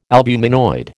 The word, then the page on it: albuminoid: American English